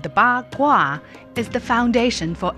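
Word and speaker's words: Ba (八): Ba, which means 8, sounds like fa, the word for prosperity, wealth, or fortune.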